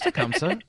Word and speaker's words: Tecumseh: And I'm like, no, that's Tecumseh.